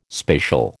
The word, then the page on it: spacial: American English